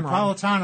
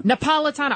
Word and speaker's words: Napolitano: I always say his name Napolitano. – Wrong. Napolitano. I want to say because he's from Naples, his family.